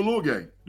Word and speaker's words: Uiagalelei: DJU Uiagalelei. That's his name.